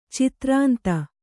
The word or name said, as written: ಚತುರಂತ